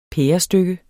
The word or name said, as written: pærestykke